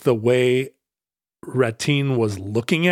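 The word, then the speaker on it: Rattín: But the reason is that Ratten was, and I hope I'm pronouncing that right, Ratin. – That sure, would be the French way. French. What is it, R-A-T-I-N? – R-A-T-T-I-N. – Yeah, it's probably Ratin.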